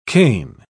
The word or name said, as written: cane